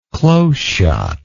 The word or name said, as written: close shot